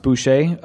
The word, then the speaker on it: Boucher: Brian Boucher, pronounced Boucher, born January 2nd, 1977, is an American professional ice hockey goaltender for the Philadelphia Flyers of the National Hockey League.